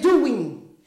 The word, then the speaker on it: Douyin: And I looked it up and it really says it's spelled like this, but the way it's spelled, it's not the way that it's pronounced. D-O-U-Y-I-N, but it's Doing.